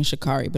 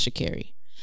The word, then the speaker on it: Sha'Carri: Sha'Carri, I was saying her name wrong last episode. I apologize. I was saying Sha'Carri, but it's Sha'Carri.